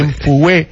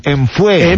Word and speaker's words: Mfuwe: Enfue, M-E... Es que no sé cómo se dice. – Enfue, enfue. – M-F-U-W-E. Enfue. – Enfue, enfue. – ¿Qué están hablando? – Enfue. Enfue, enfue, se dice.